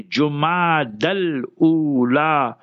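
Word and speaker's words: Jumada al-Ula: This is a month many people, ulama also, they pronounce it incorrectly. Some will pronounce it with a fatha, with a zabar, jama, that's wrong. Some will say Jumad, but not pronounced properly. So say Jumad al-Ula, Jumad al-Ukhra, Jumad al-Akhira.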